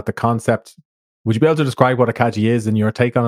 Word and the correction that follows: Ikigai: Oh, I'm sorry, most people mispronouncing it. – Yeah, so it's Ikigai.